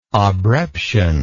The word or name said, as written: obreption